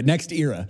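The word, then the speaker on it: NextEra: Hey, Mark, thanks so much for pronouncing it right, too, because I pronounced it Next Extra, and that's not right. That's a made-up company, apparently. So, yeah, Next Era.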